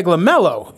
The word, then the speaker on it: LaMelo: They're going to fuck around a scumbag LaMelo.